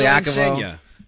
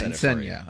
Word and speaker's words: Insigne: But when you're talking about Insigne, and I struggle with pronunciations, I want to work with enough Italians, you know. – Lorenzo Insigne. – Yeah, Insigne. All right, there you go.